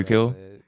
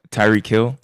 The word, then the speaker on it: Tyreek Hill: Tyreek Hill. – Say his name again. I'm sorry. – Tyreek Hill.